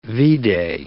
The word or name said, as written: V-day